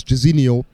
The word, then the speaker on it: Jazino: Some people could say Zeno for short, but it's Jazino, pronounced Jazino like the Brazilian footballer, for those of age, Service.